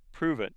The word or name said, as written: prove